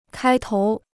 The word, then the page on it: 开头: kāi tóu